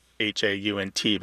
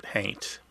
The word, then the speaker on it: haint: It's hard to know because so often the pronunciation is independent of the spelling, if that makes sense. Somebody might Right. – spell it haunt, but pronounce it haint.